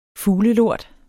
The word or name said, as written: fuglelort